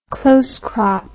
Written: close crop

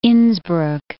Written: Innsbruck

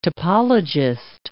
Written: topologist

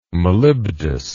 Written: molybdous

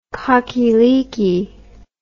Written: cockieleekie

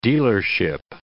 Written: dealership

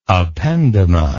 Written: ependymary

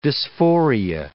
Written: dysphoria

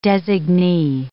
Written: designee

